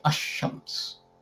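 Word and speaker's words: Ash-Shams (الشمس): So if you have a sun letter, and if you have a word beginning with the sun letter and you add Al in front of it, Lam will be dropped, like with Shams. You say Ash-Shams.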